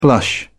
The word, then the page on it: blush: British English